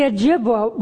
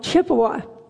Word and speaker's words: Ojibwa: The Ojibwa, when it was mispronounced, came out Chippewa.